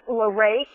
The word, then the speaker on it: LaRae: It's LaRae Quai, and it's spelled L-A, capital R-A-E, and the last name is Q-U-Y.